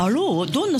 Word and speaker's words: ALOH: アロー、どんなスペルなんですか？ – ALOH ですね。これでアローと読みます。